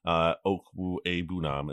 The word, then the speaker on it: Okwuegbunam: Okwuegbunam is how you say his last name.